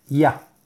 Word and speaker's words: Yah: Y dice así, trae un nombre sagrado que son las letras Yud Hei y que se pronuncia Ya.